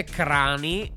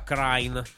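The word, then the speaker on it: Kranj: Venerdì sera arriviamo a quello che è diciamo, il confine del nostro impero perché andiamo fino a Krain in Slovenia si legge Crani, ma si pronuncia Crane.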